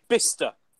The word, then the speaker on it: Bicester: Or you think, there's not H after like, the C, but maybe Bicester. Yeah. It's pronounced, right? Bister. Like it's... Imagine B-I-S-T-A.